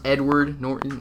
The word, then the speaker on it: Edward Norton: Edwin Norton. – All right, Kyle, you got it. All right, pronounced Edward Norton. – Oh, that's what I said. It's my accent.